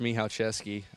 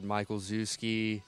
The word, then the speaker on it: Michalczewski: Well, it's pronounced Michalczewski. I try to write it down for them so they can pronounce it, but I've heard Michael Zuski. There's a lot I've heard, so it's hard to remember them all, but Michael Zuski is usually the one people go with just because that's how it looks.